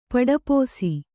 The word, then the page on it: ಪಡಪೋಸಿ: paḍapōsi